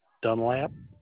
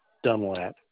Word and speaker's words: Dunlap: My name is Jeff Dunlap. – Jeff. – Jeff. Jeffrey. Dunlap. D-U-N-L-A-P. – Can you spell it for me? Spell your first name. J-E-F-F-R-E-Y.